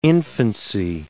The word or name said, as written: infancy